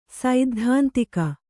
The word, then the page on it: ಸೈದ್ಧಾಂತಿಕ: saiddhāntika